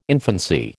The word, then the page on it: infancy: American English